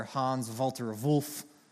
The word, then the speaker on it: Hans Walter Wolff: I love how the biblical scholar Hans Walter Wolff, he's a German, you have to pronounce it like that.